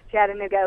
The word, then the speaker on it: Chattanooga: Am I saying that correctly? Is this Chattanooga or Chattanooga, Oklahoma? – It's Chattanooga, Oklahoma. – Chattanooga. – Like, like Chattanooga, Tennessee.